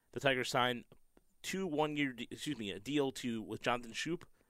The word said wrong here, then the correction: Schoop: and a one-year deal – or Scope, sorry, Jonathan Scope, as somebody pointed out. Yeah, somebody actually on Twitter, Lee Panaris, or Lee on Twitter, mentioned how to say his name.